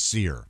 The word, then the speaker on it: Cyr: Dennis C-Y-R. – Sir? Or Seer. I knew a C-Y-R and it was pronounced Seer. – Kyre? – Okay. – Kyre? – It could be Kyre. – Kyre? – Smith? – Chire? – But me knowing a guy named C-Y-R and it being pronounced Seer...